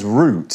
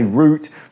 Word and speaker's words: route: Now you'll notice there I pronounce the word root as root, sounding like R O O T. That's the British way to pronounce it. In the US, people sometimes say route, but they sometimes say route. Both are okay.